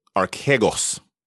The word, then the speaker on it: Archegos: around Archegos, I think is how we decided to pronounce it.